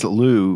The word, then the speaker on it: Looz: So this place, Loo, which is spelled Luz, L-O-O-Z, that's the French way of saying the town's name.